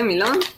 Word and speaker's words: Milo: chodzi o napoje, to chyba najbardziej popularne jest tutaj Milo, tak się y, wymawia, Milo tak jak po polsku by się przeczytało.